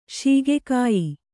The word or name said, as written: ಶೀಗೆಕಾಯಿ